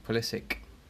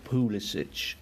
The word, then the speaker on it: Pulisic: I think Christian calls himself Christian Pulisic. Okay, so it's not Pulisic, as a lot of people.